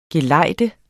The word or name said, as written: GELEJDENDE